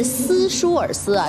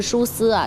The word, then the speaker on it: 舒斯: 其实呢咱们这个翻译是斯舒尔斯啊，这个就是有一点翻译的比较的多了，其实他的发音呢就是舒斯啊，其实我们可以把他当作就叫舒斯。